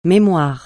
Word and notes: mémoire: francais

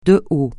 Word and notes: de haut: francais

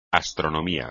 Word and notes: astronomía: espagnol

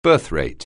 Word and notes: birthrate: anglais

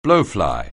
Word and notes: blowfly: anglais

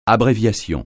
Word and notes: abréviation: francais

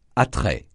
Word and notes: attrait: francais

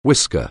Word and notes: whisker: anglais

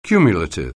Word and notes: cumulative: anglais

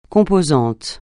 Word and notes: composant: francais

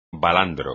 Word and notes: balandro: espagnol

